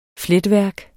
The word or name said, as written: fletværk